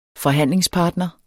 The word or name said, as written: forhandlingspartner